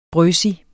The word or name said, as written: brøsig